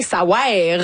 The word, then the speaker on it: Ça ouaire: Ça veut rien, ça ouaire. Vraiment, ça s'écrit de même, faut le prononcer comme ça.